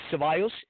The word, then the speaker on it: Cevallos: It's Michael, M-I-C-H-A-E-L, and the last name is Cevallos. It's C-E-V as in Victor, A-L-L-O-S.